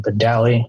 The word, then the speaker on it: Badali: My Instagram is actually my first name, Joseph, and then underscore is my last name, Badali. It's spelled B-A-D-A-L-I.